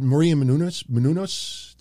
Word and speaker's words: Maria Menounos: If you go to their website, they hired Maria Menounos. That's how you pronounce her name.